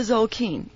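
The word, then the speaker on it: Tzolk'in: So let's talk about the Zolk'in here for a few minutes.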